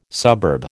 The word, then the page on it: suburb: American English